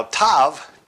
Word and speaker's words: tav: Now, tav, T-A-V, tav, is the last letter of the Hebrew alphabet. But it also meant a mark or a sign. Now, tav, T-A-V, sometimes it's actually pronounced or spelled T-A-W, is the last letter of the Hebrew word, but it also meant this mark or a sign.